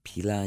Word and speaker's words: pila: Or pila, is that how you say it in Latin? – Mm-hmm.